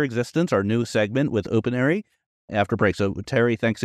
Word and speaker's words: Opinary: OpenAri, like opinion.